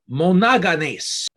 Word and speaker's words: monogenes: Now, only begotten, when we take a look at the Greek, the Strong's number is G3439, and it's pronounced monogenes.